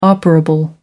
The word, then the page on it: operable: American English